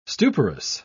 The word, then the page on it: stuporous: British English